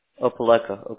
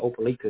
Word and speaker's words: Opelika: Opelika. Okay, got you. – They pronounce it different in Alabama than Florida. There's an Opelika in Florida. They call it Opelika.